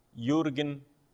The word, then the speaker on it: Jürgen: O problema é que teve um senhor chamado Jürgen Moltmann. Jürgen Moltmann se escreve Jürgen, J-U-R-G-E-N. E Moltmann, né? M-O-L-T-M-A-N-N. T-M-A-N-N, Moltmann.